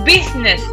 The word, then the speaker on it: business: Cuidado con la pronunciación de business. Lo deletreo. B-U-S-I-N-E-S. Business, porque la gente intenta hacer el bu. Ve una B, U, intenta el business. No, business. ¿Ok? Pero se escribe business, por si alguien no ha cogido el deletreo. B-U-S-I-N-E-S.